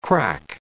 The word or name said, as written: crack